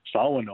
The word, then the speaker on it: Shawano: It's funny when you hear people that come from, you know, out of state, and they look at the word Shawano and they actually pronounce it correctly. They say Shawano, or they have some closer meaning to it than actual the word Shawano.